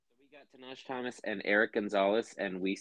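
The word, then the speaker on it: Tahnaj Thomas: 26, I have, oh man, I know I'm going to butcher it. Tanaj Thomas? – No, you said it right. Tanaj Thomas.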